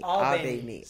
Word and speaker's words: Abeni: Abeni, like do re mi. – Abeni. – Abeni. Yes.